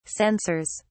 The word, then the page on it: censors: British English